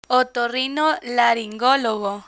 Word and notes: otorrinolaringologo: Spanish

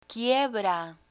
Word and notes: quiebra: spanish

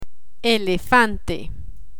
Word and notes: elefante: spanish

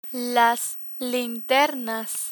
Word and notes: linternas: Spanish

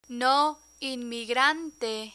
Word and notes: no inmigrante: Spanish

